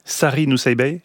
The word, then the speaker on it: Sari Nusseibeh: Sari Nusseibeh, c'est comme ça qu'on prononce.